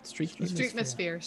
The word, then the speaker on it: Streetmosphere: We've talked about in the past about some of these plus items, like the shows in Epcot or the Streetmosphere? How do you pronounce it? – Streetmosphere.